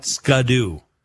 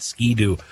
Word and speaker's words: Ski-Doo: We're talking KTM, Husqvarna, Polaris, Can-Am, BMW, Triumph, Ducati, Yamaha, Honda, and Ski-Doo, not Scudoo. Ski-Doo. That's how you say it, Ski-Doo.